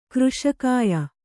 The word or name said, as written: ಕೃಶಕಾಯ